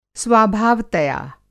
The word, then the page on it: स्वाभावतया: Hindi